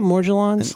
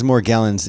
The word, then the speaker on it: Morgellons: Is that how you say it, Morgellons? I like the way that... I don't like to say Morgellon. Morgellon doesn't feel good to me. – No, it is Morgellons? – Ugh. – Yeah, it is Morgellons. Like dress, yeah. Right. Morgellons.